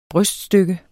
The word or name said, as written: bryststykke